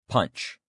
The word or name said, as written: punch